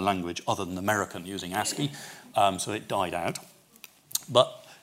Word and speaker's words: ASCII: By the way, ASCII, as it is now called, ASCII is the way you pronounce A-S-C-I-I, it is not very popular now.